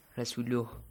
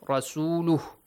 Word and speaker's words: رسوله: Rasuluh. – Yeah, Rasuluh.